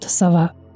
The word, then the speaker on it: Сова: Это был 17-летний Курт Сова. Сразу подчеркну, американцы произносят «сова» с ударением на первый слог.